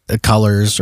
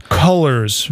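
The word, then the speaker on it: Cullors: Well, and if you are a BLM supporter, do make sure to give Taniqua Colors or whatever her fucking name is about all your fucking money.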